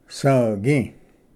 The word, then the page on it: ᏒᎩ: svgi